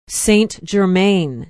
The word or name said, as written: Saint Germain